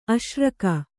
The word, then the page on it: ಆಸರಿಕೆ: kannada; āsarike